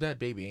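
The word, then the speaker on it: Bhad Bhabie: But apparently, I don't know if the dude and Bad Barbie are still together, but... Is it Bad Barbie or Bad Baby? It's Bad Baby, ain't it? I've been saying this girl's name wrong.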